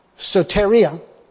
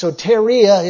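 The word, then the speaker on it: soteria (σωτηρία): But there's a word that is used that we call, it's a Greek word pronounced soteria. It's soteria, it's pronounced.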